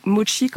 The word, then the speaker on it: mochi: Donc ça se prononce mochi comme s'il y avait un T avant le CH, puisque mochi mochi, c'est complètement différent.